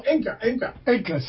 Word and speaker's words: Enka: Anka. – What is that? Anka. – The Japanese soul music. – Oh, Enka. – Sorry. Enka. Bad pronounce.